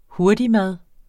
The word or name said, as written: hurtigmad